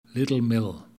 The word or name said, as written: Littlemill